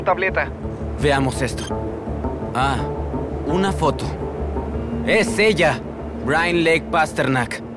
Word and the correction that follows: Brynleigh: Ah, ah creo que se pronuncia Brinley. – ¿En serio? ¿Qué deletreo más tonto?